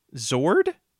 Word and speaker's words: Zord: Hey, real question: are you pronouncing it Zorg with a G? – Yes. – Why? – How do you pronounce it? – With a D. – Zord.